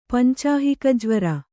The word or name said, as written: ಪಂಚಾಹಿಕಜ್ವರ